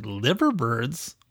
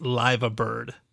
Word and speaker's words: Liverbirds: So when I first read it, I said, Liverbirds? What kind of a name is Liverbird? But no, it's pronounced Liva Bird.